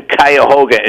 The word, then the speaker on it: Cuyahoga: I have 10 grandkids, but I visited 3 of them out in Cuyahoga. Ohio, Richmond Heights, Ohio, and I guess apparently they had a show in Cuyahoga, and Johnny couldn't even pronounce Cuyahoga.